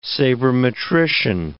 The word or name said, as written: sabermetrician